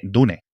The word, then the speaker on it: Dune: Y vamos a pronunciarlo constantemente Dune. – Dune. Yo lo siento mucho. Podemos decir Duna. A mí me chirría todavía más que Dune, imagínate.